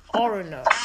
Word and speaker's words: Orono: But now you're in Orono. Wait, am I saying it right? – Yes.